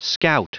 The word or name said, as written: scout